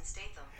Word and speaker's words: Statham: That's pronounced Jason Statham.